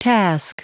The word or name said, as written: task